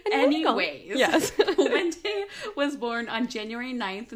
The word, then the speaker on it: Puente: Oh, Puente. – Puente.